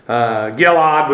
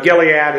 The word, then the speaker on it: Gilead: Odd, actually. Gilad would be the way we would pronounce it. But Gilead is fine.